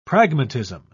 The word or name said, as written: pragmatism